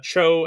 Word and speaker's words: TCHO: There's this chocolate company, and I don't know if it's just TCHO or if it's pronounced Cho, But they it's Cho, and they make their chocolate company online.